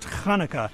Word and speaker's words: Hanukkah: It's a sports tzedakah box, Karen, and Bill, the holiday is pronounced Hanukkah.